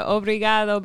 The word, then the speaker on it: obrigado: You said, obrigado, Brazil.